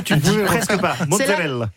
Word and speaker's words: mozzarella: Loana Belmondo, on parle de mozzarella aujourd'hui. Déjà, est-ce qu'on prononce bien ? C'est mozzarella ? – Mozzarella. – Mozzarella. Mozzarella. – Vous le dites très très bien.